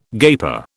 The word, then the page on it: gaper: British English